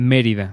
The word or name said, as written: Merida